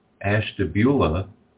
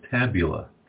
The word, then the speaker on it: Ashtabula: I had a new student today who talked about his wife who pronounced Ashtabula as Tabula.